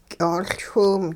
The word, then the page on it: ky'oLchwiw: hupa